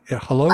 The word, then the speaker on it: Hullo: Tell us about what happened to you when you tried to book passage on, is it Hulu? Hello? How do they say that? – They pronounce it hello. So H-U-L-L-O.